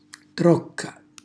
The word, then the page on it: trocca: Calabrese